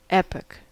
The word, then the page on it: epoch: American English